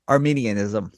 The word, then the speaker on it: Arminianism: Arminianism, can you say that right for me? – Arminianism. – That's the one, yes.